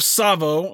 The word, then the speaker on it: Tsavo: So let's start with Tsavo. Tsavo is spelt T-S-A-V-O, so the T is silent.